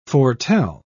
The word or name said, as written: foretell